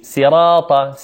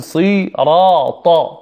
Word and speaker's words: صراط: كثير من الناس ينطقها بالسين: صراط، سي، صراط، وهذا من الخطأ. صا، نقول صا، صو، صي. يوجد فرق بين الصي والسي، صي سي، نقول صراط صراط صراط الذين.